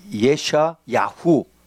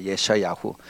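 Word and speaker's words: 예샤야후 (ישעיהו): 이사야라고 하는 그 뜻은 원래 우리는 이사야라고 하지만은 그 히브리 말로는 예샤야후 하는 것이 이사야의 그 히브리 발음입니다. 예샤야후.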